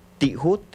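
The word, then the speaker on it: tijut: Que en la lengua yocotán se pronuncia tihut.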